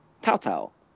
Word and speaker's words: Tatau: T-A-T-A-U. – Oh, T-A-T-A-U. – Yeah. – Tau-tau.